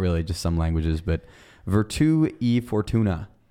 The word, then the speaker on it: Virtù e Fortuna: How do I say this? Virtù, Fortuna. – Yes, that is the correct pronunciation of the episode title. Will you say it normally? – Yeah, it's a Virtù in Fortnite, I believe. – Okay, what is it for real? – Virtù e Fortuna.